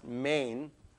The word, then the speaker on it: men: It's the word, it's spelled men, M-E-N, pronounced main.